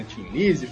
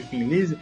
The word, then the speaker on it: Thin Lizzy: Thin Lizzy, Thin Lizzy. As duas estão corretas. É porque quem fala Thin Lizzy é em cima do nome que gerou essa grafia. Que é o Thin sem H e E no final.